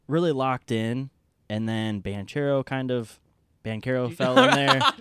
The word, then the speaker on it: Banchero: I was saying Banchero, but I think it's Banchero. So. – Yeah. The emphasis on the care.